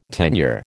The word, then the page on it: tenure: American English